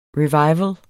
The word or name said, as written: revival